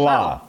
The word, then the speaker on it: Salah: The Jets coach Salad? Salad. You call the Jets coach Salad or no. Robert Salah? No, Salah? Go ahead.